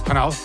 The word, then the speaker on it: Hanau: I said that right. Yes, I did. Hanau, near Frankfurt am Main.